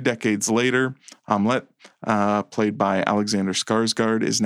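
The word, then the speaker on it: Amleth: Prince Omelette is, and I know that sounds like omelet, but that's how they say it. Omelette.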